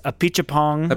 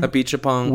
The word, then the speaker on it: Apichatpong: I think I'm not saying it right, but I think it's Apichatpong. – Apichatpong.